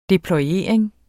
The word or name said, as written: deployering